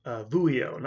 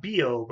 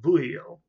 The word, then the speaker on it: Vuillot: People like, and I think I'm pronouncing this name the right way, Louis Vuillot. Vuillot, not Bio, but Vuillot,